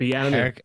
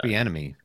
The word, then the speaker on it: Bieniemy: Eric Bieniemy, is that how you pronounce his name? God, I hate Bieniemy. Eric Bieniemy.